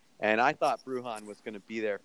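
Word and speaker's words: Bruján: Then Paul picks Brunjan, who obviously Brujan. – Okay, I'm terrible pronouncing names.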